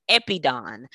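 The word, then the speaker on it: epidon (ἐπεῖδεν): So like, for instance, he looked on, it's in the Greek, it's pronounced as epidon, which means favor.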